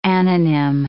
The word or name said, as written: anonym